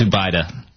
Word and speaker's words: Zubaydah: I can't pronounce his name. – I think it's Zubaydah.